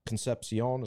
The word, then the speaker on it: Concepcion: Uh, Concepcion. Is that how you pronounce it? I believe. I think, so, I, yes. I think that's it.